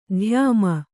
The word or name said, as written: ಧ್ಯಾಮ